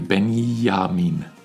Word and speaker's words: Benjamin: I think it's also because you pronounce the J differently, no? It becomes like just soft, like, yeah. – Yeah, exactly. It becomes like an I, actually. Benjamin, you know? – Right, that does sound soft.